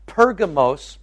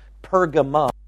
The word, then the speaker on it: Pergamos: Pergamos, or sometimes you'll hear it pronounced Pergamum, either way is correct, depending on which translation of the Bible you're reading.